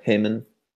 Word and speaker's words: Haemon: Yeah, Haemon.